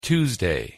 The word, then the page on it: tuesday: English